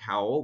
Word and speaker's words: Howell: Howell is spelled H-O-W-L. Or you can find me on LinkedIn. My name is Mark Howell, which is H-O-W-E-L-L.